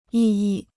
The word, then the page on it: 异议: yì yì